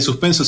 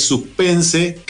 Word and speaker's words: suspense: Bueno, eh, gracias por la enseñanza de que no son series de suspenso, sino que es, suspense, que es una de las primeras cosas que me dijiste cuando viniste en el programa, me dijiste, no, no se dice suspenso, se dice suspense, cuando estaba, hablaba de las series Noir.